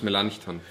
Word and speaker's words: Melanchthon: Philippus Melanchthon, Philipp Melanchthon.